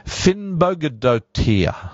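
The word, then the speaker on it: Finnbogadóttir: I, am going to say that again because that's how it's Vigidus, V-I-G-D-I-S, Finnbogadottir.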